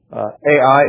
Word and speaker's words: Ai: Ai is not the proper Hebrew pronunciation, but that's the way we say it in English, so we'll stick with that.